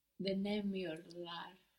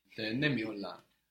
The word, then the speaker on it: denemiyorlar: And here we see again how that strong accent before the negation really helps you pronounce the entire word. Denemiyorlar. Knowing that you're putting that strong stress before the negation can help the rest of the syllables come out.